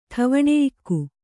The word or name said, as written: ಠವಣೆಯಿಕ್ಕು